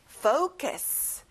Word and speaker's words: focus: 집중하다, focus. 자, 포커스가 아니라 앞에 fo 소리가 나요.